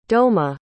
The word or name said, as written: douma